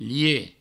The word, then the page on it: liech: provençal